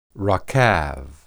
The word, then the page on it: rakab: Hebrew